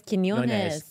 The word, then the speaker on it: Quiñones: You said it wrong. It's Quiñones. – Yeah, Frankie Quiñones. – With a hard K.